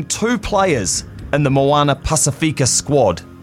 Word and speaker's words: Pacifica: Moana Pacifica, I think is how you say it. You say Pacifica, yeah.